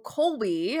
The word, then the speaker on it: Kolbe: So Kobe, and if you're listening, it's spelled K-O-L-B-E. Kobe is a conative index.